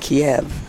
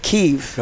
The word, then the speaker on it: Kyiv: I used to pronounce it Kiev, but now I understand the urbane urbanite pronounces it Kyiv.